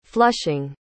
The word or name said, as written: flushing